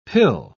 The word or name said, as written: pill